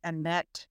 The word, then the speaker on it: Anette: I know you pronounce my name Anette because I've asked you to, but it's actually Annette, and A-N-E-T-T-E.